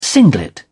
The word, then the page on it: singlet: British English